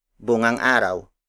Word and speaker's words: bungang araw: For example, bungang araw, bungang araw. Bungang araw. Bungang araw is spelled as B-U-N-G-A-N-G hyphen A-R-A-W.